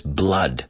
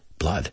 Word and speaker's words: blood: Cuidado con la pronunciación ahí. También es, no es blood, es blood. Blood.